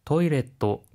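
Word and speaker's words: 토이레트 (トイレット): 하이, 토이레트, 그리고 워슈레트에는 초음이 들어가고요. 페이퍼에는 자음이 두 개 들어가는 거에 주의해 주세요.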